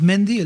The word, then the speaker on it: Mandy: Se escribe Mandy, pero es Mendy el tema.